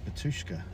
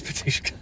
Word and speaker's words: Batushka: BATUSHKA. – It is BATUSHKA.